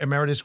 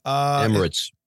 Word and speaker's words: Emirates: Well, the state-owned flagship Dubai carrier, a subsidiary of Emeritus Group. – Emirates.